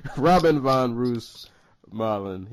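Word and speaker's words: Robin van Roosmalen: and Robin Von Rusev. I could keep butchering this dude's name. Robin von Roosmalen.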